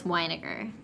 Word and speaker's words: Weiniger: And it's by, its host is Bridger Weiniger. – Uh-huh. – But his last name is spelled like vinegar, but it's Weiniger. Like, that's how you say his last name.